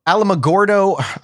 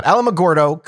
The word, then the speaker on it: Alamogordo: The next day, the one important thing that happened on the 10th of July is that Major Pritchard from Alamogordo, I said that right, thank God, Alamogordo claimed that there had been a unit from his base in Roswell that had launched balloons around June 14th.